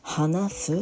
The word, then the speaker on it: 話す: Hanasu, c'est H, H, A. Donc, on prononce HA, HA, Hanasu.